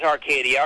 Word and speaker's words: Arcadia: Yeah, you're with, am I saying this right, Arcadia Publishing? – Yes, it is. It is Arcadia.